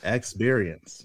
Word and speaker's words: ExBeerience: That's how you say it. ExBeerience. Yes. – ExBeerience.